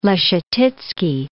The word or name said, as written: Leschetizky